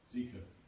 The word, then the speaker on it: Zika: Zika.